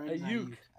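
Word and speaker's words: Aiyuk: It's definitely Ayuk. Wrong. I just screwed that up. – Ayuk.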